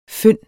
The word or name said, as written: fynd